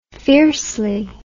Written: fiercely

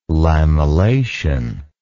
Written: lamellation

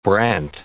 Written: brant

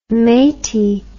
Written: maty

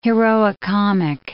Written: heroicomic

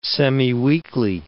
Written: semiweekly

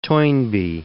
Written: Toynbee

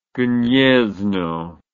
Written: Gniezno

